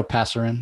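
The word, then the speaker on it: Pasarín: It was written by Jeremy Adams and Fernando Passerine. Am I say that right? Not too sure. But Yeah, Fernando Passerine, yeah.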